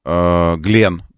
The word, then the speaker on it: Glenn: Согласно трансляциям сайтов и Space, мегаракета New Glenn, Glenn, правильно говорить, ну, в честь астронавта, компания Blue Origin взорвалась во время испытаний на стартовой площадке на мысе Канаверал.